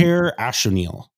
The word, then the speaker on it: Ojer Axonil: Ojer Axonil is how you say it.